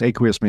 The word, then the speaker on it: Aqueous: Aqueous Technologies. Aqueous means water. Aqueous, or some people say aqueous.